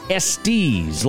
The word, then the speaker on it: Estes: And I can tell you right now, Thad Estes, who was on, did I say that right? Thad Estes. Estes, like Rusty's in the movie Cars.